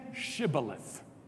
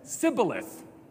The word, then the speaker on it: Shibboleth: And when they said no, they said to him, "Then say Shibboleth." And he said, "Sibboleth," for he could not pronounce it right.